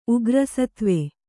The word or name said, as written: ಉಗ್ರಸತ್ವ